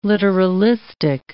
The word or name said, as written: literalistic